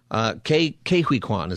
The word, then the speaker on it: Ke Huy Quan: Ke Huy Quan is how you say his name.